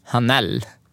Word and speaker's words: Hanel: Hon heter Suzanne Hanel. Om jag uttalar efter namnet rätt. – Hanel? – Ja.